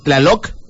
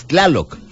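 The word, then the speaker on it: Tláloc: Pero no es Tlaloc, es Tlaloc, el dios de la lluvia.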